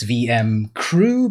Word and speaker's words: VMC crew: Well, the pronunciation's weird because I am borrowing the C from VMC, but also the C for crew. So you just have to see it. It's VM crew, but I say it Crew.